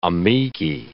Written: amici

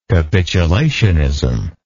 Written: capitulationist

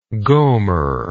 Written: gomer

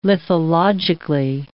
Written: lithologically

